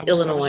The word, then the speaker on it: Illinois: You don't pronounce the S at the end. It's just Illinois.